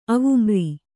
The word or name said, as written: ಅವುಮ್ರಿ